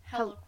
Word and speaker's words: Helle: Hella, Ella, Hella. – Hella Crafts. It's spelled like Helly, like H E L L E.